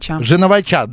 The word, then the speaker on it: Женовача: В постановке Сергея Женовича уже более 20... Женовача, да, извините, ради бога, неправильно поставил ударение.